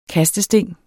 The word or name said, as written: kastesting